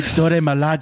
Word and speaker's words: Dora Milaje: Okoye joined the, oh my God, the Dora the Explorer Majali. What is it? – The Dora Milaje.